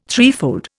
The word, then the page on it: threefold: Indian